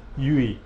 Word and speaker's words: UWI: I'm moving again over to the west of London, the University of West London, but I did work here, the University of West Indies, UWI, it's pronounced UE.